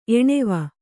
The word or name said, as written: ಎಣೆವ